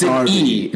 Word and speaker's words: Derby: It's pronounced Derby. – It's spelled that way. All right. – I know. – It's ridiculous, but... – That's how it's pronounced.